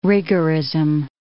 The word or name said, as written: rigorism